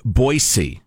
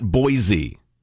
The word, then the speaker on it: Boise: Uh, uh, I don't know the title of it, but the long and short of it is there is no Z in Boise. It's pronounced Boise, hmm. not Boise.